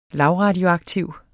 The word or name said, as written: lavradioaktiv